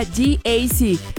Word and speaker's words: GAC: Se escribe GAC, pero se pronuncia GAC.